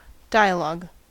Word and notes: dialog: American English

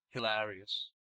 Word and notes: hilarious: American English

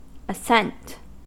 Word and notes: ascent: American English

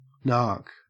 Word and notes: narc: Australian English

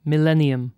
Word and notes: millennium: British English

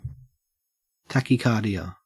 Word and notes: tachycardia: Australian English

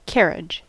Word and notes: carriage: American English

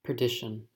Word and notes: perdition: American English

